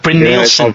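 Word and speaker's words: Brynildsen: That's right, it's Eivind Brunelton. We love pronunciations on this programme, don't we? Someone please shout it. – Bryn Nielsen. Yeah, Bryn Nielsen.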